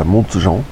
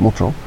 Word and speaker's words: Montjean: Euh, dans un village qui s'appelle Montjean, parce il se prononce comme ça, Montjean. Voilà. Moi j'aurais dit Montjean, mais ils disent Montjean.